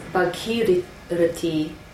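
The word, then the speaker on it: Bhagirathi: Bhagirati. – Long A, long E.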